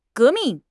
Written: 革命

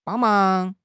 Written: bang1 mang2

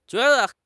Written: jue2 de5

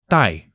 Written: dai4

